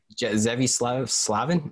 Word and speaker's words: Zevi Slavin: Zevi Slavin, is that how you say your name? – Yeah.